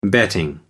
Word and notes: betting: American English

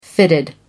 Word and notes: fitted: American English

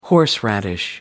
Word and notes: horseradish: American English

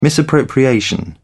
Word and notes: misappropriation: British English

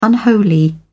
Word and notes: unholy: British English

